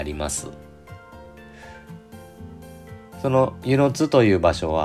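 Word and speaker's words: 温泉津: 私、母の生まれがですね、島根県の湯の津というところです。温泉に津って書いて、湯の津って読みます。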